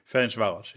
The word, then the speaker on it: Ferencváros: Ferencváros, du kannst es besser aussprechen. – Ferencváros, ja.